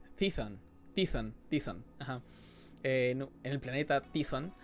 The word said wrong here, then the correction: Tython: que Tython, Tython, así se pronuncia, Tython.